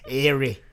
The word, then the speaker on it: Eyre: Australian listeners can explain how you pronounce E-Y-R-E. – It's probably E. – Erie.